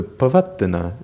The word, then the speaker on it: Pavattana: So notice with the Pavattana, that's at the beginning of a word actually. The word is Pavattana, so you can't pronounce a double consonant at the beginning of a word. That'll only be noticeable in the middle.